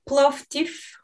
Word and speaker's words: Plovdiv: It's called Plovdiv.